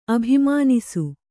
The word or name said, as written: ಅಭಿಮಾನಿಸು